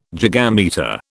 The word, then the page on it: gigametre: British English